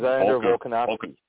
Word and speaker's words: Alexander Volkanovski: Alexander Volkanovski.